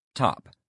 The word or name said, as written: top